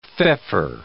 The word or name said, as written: feoffer